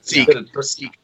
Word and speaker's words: Sikh: Is that how they pronounce it? Or Sikh? – Sikh.